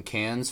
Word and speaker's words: Cannes: It actually premiered at the 2009 Cannes Film Festival.